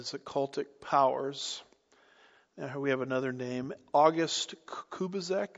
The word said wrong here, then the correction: Kubizek: Does that sound right? How do you say that? – Kubizek. – Okay. I mangled a few syllables there.